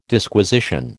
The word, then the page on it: Disquisition: American English